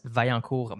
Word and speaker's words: Vaillancourt: My last name and first name are very hard to pronounce in English. So if you're a French Canadian, I'm going to say it in French Canadian. That's Vaillancourt Max, all in one word.